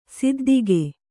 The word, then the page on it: ಸಿದ್ಧಿಗೆ: siddhige